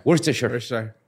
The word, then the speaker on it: Worcestershire: Se escribe Worcestershire, pero se dice Worcestershire. Worcestershire. – Ajá, se escribe Shire, pero se dice Sure.